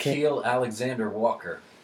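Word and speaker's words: Nickeil Alexander-Walker: Is it Nik- Nickeil Alexander Walker? – Okay.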